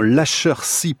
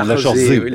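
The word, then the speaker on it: Laacher See: Patrice nous dit, bien moins connu que Yellowstone, le super volcan allemand Lachersee, près de Bonn. Est-ce que je le prononce bien? – Non, Lachersee,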